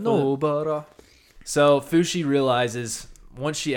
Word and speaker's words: Nobara: How do you say it one more time? – Well, we haven't had, you know, we record two episodes at a time, so we haven't had our pronunciation lesson yet. But at the moment, I was going Nobara.